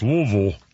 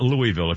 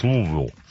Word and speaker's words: Louisville: and in Louisville, I'm sorry, that's pronounced Louisville. For the record, it's not Louisville. If you ever say Louisville in Kentucky, they'll throw something at you. It's Louisville. Anyway, they have a Black History Museum in Louisville known for shackling white people.